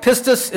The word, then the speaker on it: pistis: It's pronounced pistis, I think, pistis. And you find this word 244 times in the New Testament. It's found 24 times in the book of Hebrews. And it simply means just a mental assent, kind of, it, that you strongly believe this, though you haven't seen. But it's a noun form. But then there's the verb form of the word. It's spelled similar but pronounced a little differently.